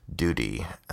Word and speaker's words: Duty: And Michael Duty, I had to actually email him this past evening to make sure I pronounced his name right. Duty. I'm sorry. I'm sorry that I did ask. I would have pronounced it in some weird French way, my friend.